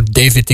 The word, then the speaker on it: David: En daarnet hadden we Damiano David. Ik zeg altijd David, misschien is het gewoon David, hè? Je kan het op twee manieren uitspreken natuurlijk.